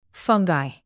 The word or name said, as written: fungi